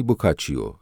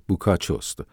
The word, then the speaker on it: بوکاچو: اولاً این داستان وامگیری پرو از جوانی بوکاچیو یا به تلفظ صحیح تر بوکاچوست و خودش هم این موضوع را اعتراف کرده.